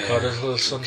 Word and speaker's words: Richarlison: Richarlison, is it Richarlison, the Brazilian for Watford, new signing? Not sure of the pronunciation. – Oh yeah, yes, Ilson, you know. – Yeah. – Richarlison.